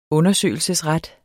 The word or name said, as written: undersøgelsesret